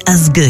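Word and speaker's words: Ásgeir: La version qu'on vous propose ce soir nous vient d'Islande et d'un artiste électro-folk délicat, Asgeir, qu'il faut prononcer Asgeir, il paraît.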